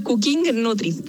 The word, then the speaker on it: Cooking Nutri: Se llama Cooking Nutri, que es cooking de cocinar, como se escribe COOKING y Nutri de nutricionista.